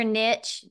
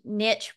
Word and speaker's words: niche: And whether you say niche or niche, niche rhymes with rich. So we're going to go with niche.